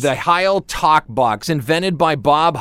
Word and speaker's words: Heil: I've also heard it pronounced Heel talk box. Every time I've seen one, every time I've talked to somebody about it, it's called the Heil talk box or just the talk box.